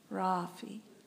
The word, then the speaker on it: Rafi: Rafi. Rafi. So it's spelled R-A-F-I. The emphasis is on the ah, the first ra, ah.